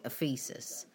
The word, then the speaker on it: Ephesus: It's called Ephesus. I think that's how you pronounce it. That's how the woman pronounced it when she answered the phone. It's Ephesus. I would have called it Ephesus, but that doesn't sound much better, to be quite honest.